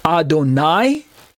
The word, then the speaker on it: Adonai: Adonai versus Adoni. It's the vowel sound at the very end, the second syllable vowel sound.